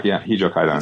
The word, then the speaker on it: Hijokaidan: Am I pronouncing that right? – Yeah. Hijokaidan. – Hijokaidan, yeah.